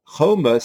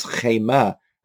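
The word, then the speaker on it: choma (חמה): Now, even though it can still be pronounced as choma, and the vav is silent, but without the vav, could actually turn the word into a different word. And if you pronounce it slightly differently, not as choma, you can actually pronounce it as chema, as fury and wrath.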